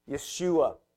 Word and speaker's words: Yeshua: Yeshua is how it would have been pronounced.